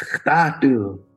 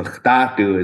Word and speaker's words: khtatu: And then she'd say, it's khtatu. So khtatu is the fast way of pronouncing that. Like khtayetu turns into khtatu.